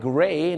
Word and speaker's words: gray: gray g r e y gray เนี่ยนะฮะชาวอเมริกาจะเป็น g r a y ก็เป็น gray นั่นเองครับอย่าลืมนะครับคำนี้ต้องออกเสียงรอเรือเพราะบางคนเนี่ยนะฮะเห็นฝรั่งใส่เสื้อสีเทามาไปบอกฝรั่งว่าโอ้ I like your gray shirt